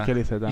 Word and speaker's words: Mikel Iceta: Por cierto, igual que a Müller, a Mikel Iceta se le pronunciará Mikel. – Mikel Iceta. – Mikel Iceta, no Mikel.